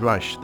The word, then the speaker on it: Reich: And he, in turn, got this from Steve Reich, the composer. Apparently that's the correct way to pronounce it, which I've been doing wrong for years.